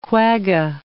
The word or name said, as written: quagga